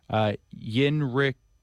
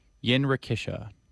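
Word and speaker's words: Jinrikisha: Blue Bossa, La Mesa, Recorda Me, and excuse me on this pronunciation. It is somewhat difficult. Yinrikisha. Yinrikisha.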